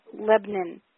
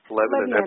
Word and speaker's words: Lebanon: And I just got an education on how to pronounce Lebanon with two syllables calling it Lebanon. – Lebanon, absolutely.